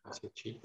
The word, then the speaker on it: Chi: How do you say that word? Is it chi method? – Chi.